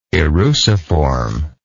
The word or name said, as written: eruciform